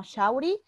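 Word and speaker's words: Shaury: Me pueden seguir como Shauri. Mi nombre se escribe S-H, S de sapo, H-A-U-R-Y, rayita abajo, Pevency, que se escribe eh, con V, Pevensie.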